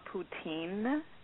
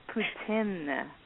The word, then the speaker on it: poutine: Can I just say it's not poutine? – Uh-oh. – Thank you. – It's what is that? – It's pronounced poutine.